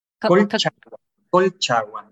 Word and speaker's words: Colchagua: And so the winery itself is located in Casablanca, but you also have vineyards in, and I'm going to pronounce it wrong, Colchagua? – Colchagua, yes. – Thank you. – It's a different, difficult word. Colchagua is it's a Mapuche language. You know, it's our ancestors. So it's not easy to pronounce.